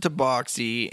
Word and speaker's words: tabaxi: You pronounce it tabaxi, and you're literally the person that introduced me to understanding that it's actually pronounced tabaxi, because I always pronounced it tabaxi. And so now I want you to name other English words that use the AX to make an aux sound, like the word aux or box.